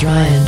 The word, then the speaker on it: Ruyun: A family cousin, Ryan Green, spelled R-U-Y-U-N, but pronounced Ryan, just landed a feature on ABC News for his Pay It Forward initiative.